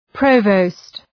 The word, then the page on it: provost: ‘prəʋvəʋst